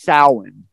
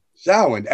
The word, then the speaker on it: Samhain: It's pronounced Samhain. – Samhain, almost like S-A-W-E-N. So that M is it's a soft M, and that's common in that tongue.